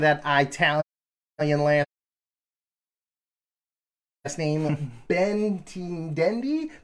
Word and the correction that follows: Benintendi: whatever. If you're a Royals fan, you're probably checking in, you're like, Jesus Christ, this guy can't pronounce this guy's name. – Benintendi.